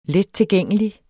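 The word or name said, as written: lettilgængelig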